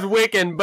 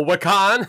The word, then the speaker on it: Wiccan: Yeah, the character's name is Wiccan, but my dumb ass was like, oh, Wakan?